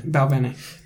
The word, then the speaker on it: Balvenie: Balvenie.